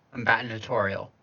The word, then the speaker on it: combinatorial: C-O-M-B-I-N-A-T-O-R-I-A-L. – Combinatorial. – Combinatorial. – Yeah, that you got it.